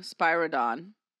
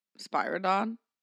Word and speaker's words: Spyridon: Did I say that right? – Nicholas? Like Santa? – No, Spyridon. Spyridon.